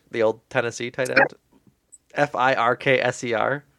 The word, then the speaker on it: Firkser: How would you pronounce it, Jordan? Through your through your lens. Uh, no, I think you're right. It's just a funny name, Tony Firkser.